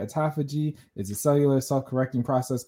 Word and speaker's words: autophagy: The question was, what is autophagy? Am I saying it right? – Autophagy.